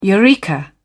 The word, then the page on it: eureka: British English